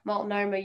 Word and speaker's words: Multnomah: Multnomah University.